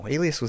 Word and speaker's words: Alias: Alice Smith and Jones premiere on ABC. – Ooh. – Alice Smith and Jones? – Is that how you pronounce it? A-L-I-A-S? – Oh, Alias.